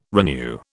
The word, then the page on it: renew: British English